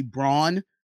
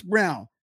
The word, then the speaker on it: Braun: He says his last name is pronounced as Brown, but it's okay.